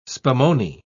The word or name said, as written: spumoni